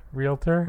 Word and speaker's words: realtor: Realtor? – Realtor? Fuck you, I don't know. – There's no extra vowel in there. Realtor.